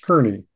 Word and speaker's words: Kerney: It's Kerney.